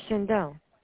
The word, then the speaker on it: Shaindel: No, Shindel. S-H-A-I-N-D-E-L. – S-C-H-A-N-D-I-L? – S-H-A-I-N-D-E-L.